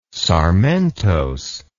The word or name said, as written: sarmentose